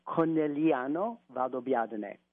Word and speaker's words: Conegliano Valdobbiadene: It's pronounced Conegliano Valdobbiadene. – Okay. – So anytime in Italian, anytime you see a GLI, It's not pronounced gli, it's pronounced yi.